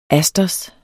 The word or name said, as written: asters